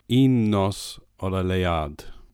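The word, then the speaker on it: Un Nos Ola Leuad: Well, I heard a Welsh speaker say it, so I think okay. It goes, Un nos ola leard.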